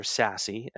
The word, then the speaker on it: SASE: The adoption of Secure Access Service Edge, or SASE, as it's meant to be pronounced, the acronym, has pulled those teams together in a big way.